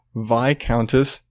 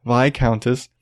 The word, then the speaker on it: Viscountess: And again, old ladies, 81-year-old British, and I'm probably gonna mess this up, and I've seen it and I've heard it, Viscountess, there's an S, I've heard you don't pronounce the S, whatever, Viscountess, has requested permission to use a machine gun to exterminate badgers on her estate.